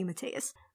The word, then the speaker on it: Matthias: It's got to be Matthias. Let's check IMDb really... Yeah, yeah. Matthias. I guess the H is silent. – Yeah. At least that's how they seem to pronounce it in the show.